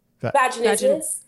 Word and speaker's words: vaginismus: Vaginismus. Yeah. – Oh, it sounds like a like a monster in a Godzilla movie or something. – I, it it's does. Really like Godzilla versus vaginismus.